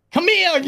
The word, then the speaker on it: Kamiya: And I think that Hideki Kamiya, did I say it right? – Kamiya, you f***.